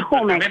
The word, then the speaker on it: Jumex: Está en Latinoamérica, perdón, tiene el museo, no sé si se pronuncia Jumex. – El Jumex, el Jumex en México, sí.